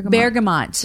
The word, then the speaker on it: bergamot: Bergamot? – Bergamot.